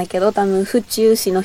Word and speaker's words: 府中: 府中のアクセントは、宇宙じゃなくて、地球の方。